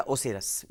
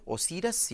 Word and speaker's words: اوسیرس: اوسیرس کی اصل بنیاد یا اوسیرس یہ دونوں تلفظ آتے ہیں اوسیرس یا اوسیرس